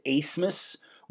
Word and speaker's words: Asmus: I guess I was calling him Max Abmus because that's how you spell it, but I guess he pronounces it Asmus, which is, I mean, whatever.